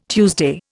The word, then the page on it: Tuesday: Indian